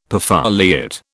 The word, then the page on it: perfoliate: British English